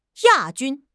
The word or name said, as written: ya4 jun1